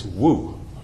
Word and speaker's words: Wu: Just say that the Mu is spelled M-U. It's a Japanese word. In Chinese, it's Wu, W-U.